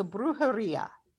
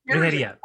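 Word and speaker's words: brujería: Brujeria. Brujeria. Yeah. Because it's on the Ia is the accent, right? So Brujeria. – Yeah. Brujeria.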